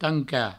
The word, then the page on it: tancar: provençal; tanca